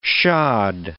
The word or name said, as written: shod